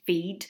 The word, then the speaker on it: Fiid: And we mentioned at the start about the name of the business, Feed, but it's spelled F-I-I-D, pronounced Feed, as in exactly. – Feed yourself, but spelled F-I-I-D.